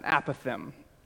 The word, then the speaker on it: apothegm: Apothegm joins the the club of words that are not exactly pronounced the way they're spelled. A more notable word might be colonel. No, seriously, it should be spelled K E R N E L, or it should be pronounced a completely different way.